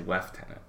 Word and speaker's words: lieutenant: It's like how the old Brits say lieutenant. They say lieutenant.